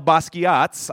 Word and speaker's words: Basquiat: You see, recently, just one of Jean-Michel Basquiat's, I had to look up how to pronounce that because it doesn't say, you don't pronounce it the way it looks.